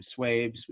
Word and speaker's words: Swaybez: Swaby is spelled S-W-A-B-Y. Other than that, I am on both Twitter and Twitch under my nickname, Swabes, which is S-W-A-Y-B-E-Z.